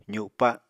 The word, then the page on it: zupa: apatani